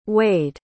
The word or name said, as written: waid